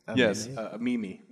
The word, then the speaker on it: meme: I didn't know what a mem was until I was a much older man. – A mem? – A mem. That's how you pronounce it, right? A meme? – Yes, a meme.